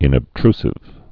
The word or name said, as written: inobtrusive